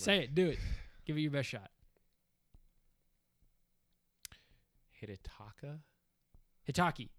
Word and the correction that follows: Hidetaka: No. – It's H. It's Hide. Hidetaka.